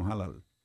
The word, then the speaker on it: halal: A pronúncia halal? – Eles falam halal.